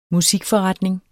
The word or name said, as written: musikforretning